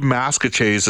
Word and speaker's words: Maskwacis: It's now called Ma- I believe Maskwacis is the proper pronunciation of it.